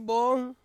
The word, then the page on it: on: Ansus